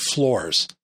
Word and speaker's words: FLRS: A common process used by professionals for autofocus is called FLRS, or I pronounce it FLORS.